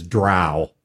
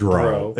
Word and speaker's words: drow: The online pronunciation guide from Wizards of the Coast says it is drow, not drow. It is not drow.